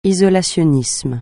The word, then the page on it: isolationnisme: francais